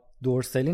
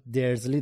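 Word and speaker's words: Dursley: یا دورسلی نداریم، درزلی داریم و کلی اسامی دیگه.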